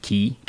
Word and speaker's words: Key: I just call it Key, K-E-Y.